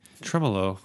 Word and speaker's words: Tremolo: What is the correct way to say it? – Tremolo. – Tremolo. – Tremolo. Okay.